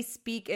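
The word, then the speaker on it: Speake: Wendy Speak, it's S-P-E-A-K-E. Silent E at the end.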